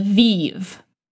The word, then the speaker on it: Vive: Vive.